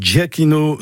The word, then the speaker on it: Giacchino: Shakira et Michael Giacchino Zou ou vous l'aurez compris.